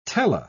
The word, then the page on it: tele-: British English